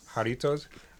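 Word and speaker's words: Jarritos: Jaritos.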